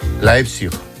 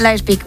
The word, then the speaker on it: Leipzig: Leipzig. – Leipzig. Leipzig, ¿ves? – Leipzig, muy bien.